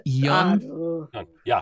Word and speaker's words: Yeon: How would you say his last name? Y-E-O-N. Yeon? Yeon? – Yeon? – Yeah.